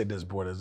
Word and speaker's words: Desbordes: And how is it pronounced? Desbordes. – Yeah. You can say Desbordes. That shit French.